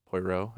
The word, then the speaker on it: Poirot: like Agatha Christie novels, you know, they have the main detective, Poirot, I think is how you say his name.